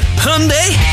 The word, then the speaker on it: Hyundai: So on the show this morning, we're talking about words that we can't pronounce, because I've been saying Hyundai wrong my whole life. It's Hyundai! I'm going to get a brand new Hyundai! The Americans were right about it!